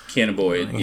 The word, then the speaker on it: cannabinoid: Is that how you pronounce it? No, cannabis. – Yes, cannabinoid.